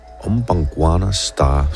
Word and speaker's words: Umbangwana Star: Umbangwana Star, simpelthen. Det er sådan, det skal udtales.